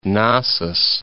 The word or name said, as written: Gnossus